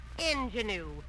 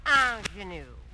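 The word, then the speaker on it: ingénue: Mr. Haley, the word is not ingenue. It's a French word, and it's pronounced ingenue.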